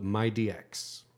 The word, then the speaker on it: MyDX: M-Y-D-X. – Oh, MyDX. Oh, the M D-X. And the D, okay. Yeah. That's like Mighty X. – No. – Just so it's my DX.